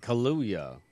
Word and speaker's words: Kaluuya: Kaluuya.